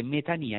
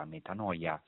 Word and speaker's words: metanoia: Interessante perché metania in greco, che sarebbe anche secondo la pronuncia nostra metanoia, significa conversione o cambiamento di mentalità.